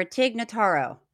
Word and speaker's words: Tig Notaro: Let's hear it for Tig Notaro.